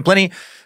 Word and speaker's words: Pliny: The ancient Roman historian Pliny, I always wanted to call him Pliny, but apparently he doesn't rhyme with piney. The ancient Roman historian Pliny suggested that Phoenician merchants had made the first glass in the region of Syria around 5,000 BCE.